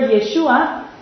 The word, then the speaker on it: Yeshua: Because the word Yeshua in first century land here was the way they pronounced the Old Testament name Yehoshua, Joshua.